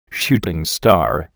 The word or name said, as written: shooting star